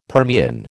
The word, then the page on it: Permian: American English